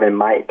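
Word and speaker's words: mate: คำว่าเมทหมายถึงเพื่อนนะครับ MFTE เมทแต่ว่าออกเสียงออสซี่กลายเป็นไมค์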